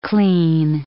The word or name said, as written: clean